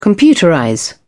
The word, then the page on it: computerize: British English